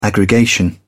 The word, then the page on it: aggregation: British English